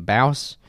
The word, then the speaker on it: Bouse: I don't know how to say his last name. Bouse B O U S E.